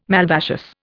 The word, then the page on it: malvaceous: Indian